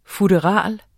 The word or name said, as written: FUTTERALERNES